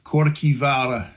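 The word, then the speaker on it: Kirkjuvágr: It's actually pronounced Corkivara in Old Norse.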